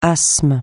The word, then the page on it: asthme: francais